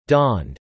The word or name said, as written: dawned